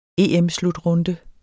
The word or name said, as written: EM-slutrunde